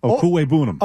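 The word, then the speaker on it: Okwuegbunam: Okuwebunam? Is that how you pronounce it? – Okuwebunam. – Okuwebunam. Great name.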